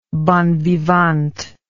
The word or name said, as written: bon vivant